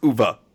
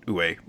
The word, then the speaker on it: Uwe: guys, we have been saying it wrong the whole time. Like, I even got a, somebody from Germany to pronounce it correctly for me, and it's Uva. Uva. And I think all but one time during that episode, I said Uwe. So it's Uva. We got to remember that. Uva.